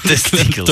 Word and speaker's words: testikels: Aha, de klemtoon lag verkeerd. – Testikels.